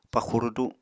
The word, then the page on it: paxu rudu: Apatani